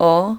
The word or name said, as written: a